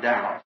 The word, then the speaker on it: Daugh: Not really in his name, he's a German, D-A-U-G-H, and I also pronounce it Dow. – Sono nadi mzinarake iyo ndi mu Germany, D-A-U-G-H.